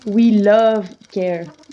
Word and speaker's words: Wheel of Care: Donc ça s'écrit Wheel of Care, donc la roue du soin, mais ça se prononce We Love Care.